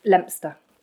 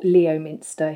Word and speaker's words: Leominster: born in a little town that nobody knows, and then all of your poddies will probably know it, Leominster, okay. – Or Leominster, if you want to say it how it's spelt.